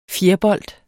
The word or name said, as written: fjerbold